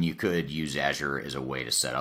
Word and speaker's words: Azure: but when you look at Azure, right? I'm saying it right, Azure.